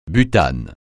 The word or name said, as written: butane